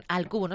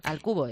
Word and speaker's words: Al Cubo: Al Cubo, no sé si lo he dicho bien. Al Cubo es, ¿no? – Lo has dicho perfecto.